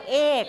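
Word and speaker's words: eight: เราจะออกเสียงว่าเอทซึ่งเหมือนกับคำว่าไข่ซึ่งไม่ใช่ต้องออกเสียงว่าเอทเอทไม่ใช่เสียงกอไก่นะ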